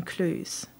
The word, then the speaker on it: clues: Ma mère m'avait expliqué il y a longtemps comment prononcer le mot clues en anglais. Un peu comme rue, et pas du tout comme ruée, la prononciation française du Cluedo.